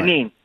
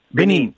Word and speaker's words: Benin: Uh, now, um, hopefully, I'm, is it Benin or ben, Benin? I want to try and pronounce it right. – Benin. Benin. Benin. Okay, there we go.